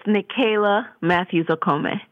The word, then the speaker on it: Nicaila Matthews Okome: I want to make sure I'm saying your name correctly. Can you enunciate enunciate it for me? – Sure, it's Nicaila Matthews Okome.